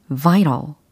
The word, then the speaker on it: vital: remain vital, remain vital. remain이라고 하면 남다라는 거잖아요. 근데 뒤에 들린 단어는 vital입니다. V I T A L vital이라고 하셔도 되고 vital이라고 발음하셔도 되겠죠.